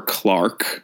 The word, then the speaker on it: clerk: So I suppose we could say that our victim is our titular city clerk or Clark. We do realize that it is pronounced Clark in the UK, but we are going to say clerk throughout.